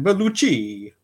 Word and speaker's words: Bellucci: Did I say that wrong? Should it have been Bellucci? – Yep, that was it.